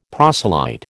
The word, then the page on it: proselyte: American English